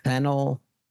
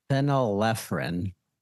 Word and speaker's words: phenylephrine: Phenylephrine. – Phenylephrine.